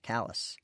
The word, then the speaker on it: Calais: Jack gave a French spin to C-A-L-A-I-S, making it Calais, not the Vermont-style callous.